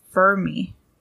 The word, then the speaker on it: Fermi: I said that wrong. I want to say it's Fermi.